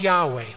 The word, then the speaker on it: Yahweh: If you like Hebrew, it's pronounced Yahweh. Y-H-W-H.